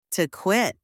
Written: to quit

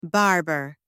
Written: barber

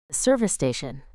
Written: service station

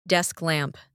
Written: desk lamp

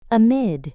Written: amid

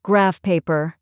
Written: graph paper

